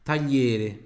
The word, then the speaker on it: tagliere: Il tagliere. Il tagliere. Il tagliere. Tagliere. Aqui tem uma pronúncia extremamente importante também, que é do GLI, que tem som de LHI.